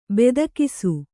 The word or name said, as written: ಬೆದಕಿಸು